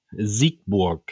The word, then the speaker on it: Siegburg: And thanks for the pronunciation, though. Siegburg. – That's correct.